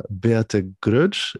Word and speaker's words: Beate Groetsch: today I'm super happy to have for this discussion Beate Groetsch.